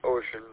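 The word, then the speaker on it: Wouxun: Ocean, even though it's pronounced Ocean, but it looks like it's spelled like and pronounced like Wuxon.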